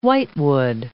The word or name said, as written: whitewood